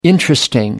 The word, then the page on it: interesting: American English